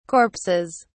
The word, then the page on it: corpses: British English